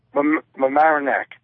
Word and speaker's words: Mamaroneck: How do you pronounce the name of your city? – Mamaroneck. – Ah, there you go. That sounds right.